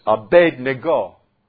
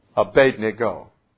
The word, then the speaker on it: Abednego: And the closest one to our normal pronunciation is this one. And it's Abednego. Abednego.